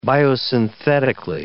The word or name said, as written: biosynthetically